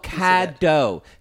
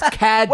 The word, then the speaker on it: Caddo: Still looking for a piece of By the way, y'all, it's spelled Caddo. C-A-D-D-O. Everybody thought it was Cattle Street. It's Caddo. – Well, it's not. Doesn't help when you basically slur it. – Well, C-A-D-D-O, Caddo Street Barbecue.